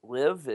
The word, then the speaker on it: Liv: I think you pronounce it Liv. Is that correct, guys? L-I-V? – That's accurate.